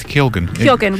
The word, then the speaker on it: Keoghan: We are following Barry, I'm going to say Keoghan, I believe he pronounces his name, I would have said, having not heard him say it, Keoghan, as you just did then, but I watched an interview with him where he said that I think he was adopted or something similar and doesn't know how it's said, so he's gone with Keoghan.